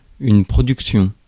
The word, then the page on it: production: France; [pʁɔ.dyk.sjɔ̃]